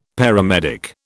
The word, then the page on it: paramedic: British English